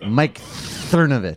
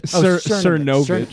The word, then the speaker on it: Cernovich: But listen, here's the other thing I want to say to Mike Cervonich. Am I pronouncing his name right? – Mike Cernovich. – Cernovich.